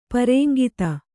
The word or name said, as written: ಪರೇಂಗಿತ